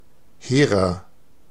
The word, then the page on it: hehrer: /ˈheːʀɐ/